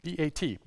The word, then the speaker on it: bat: In Hebrew, the term for daughter is bat, B A T, bat. bat which is the first syllable of Bathsheba.